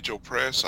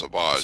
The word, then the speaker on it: Savage Opress: These, I'm assuming, will be 12-inch, 1/6 scale figures, and that'll be a Clone Commando, a Savage Opress. I think it's pronounced Savage. – Savage.